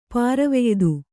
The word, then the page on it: ಪರಿವ್ಯಾಧ: pari vyādha